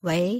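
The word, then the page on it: wei: Chinese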